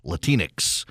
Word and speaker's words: Latinx: They sure as heck don't call themselves Latinx, rhymes with Kleenex.